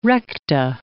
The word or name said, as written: recta